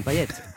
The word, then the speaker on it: Payet: Ah bah moi écoutez, si je suis venu parler de la Réunion, faisons-le à la créole, disons Payet. – Payet, Payet. – Non mais c'est vrai parce qu'à la Réunion, le T de Payet se prononce, ouais.